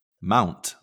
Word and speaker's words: Mount: But Mount rhymes with Count.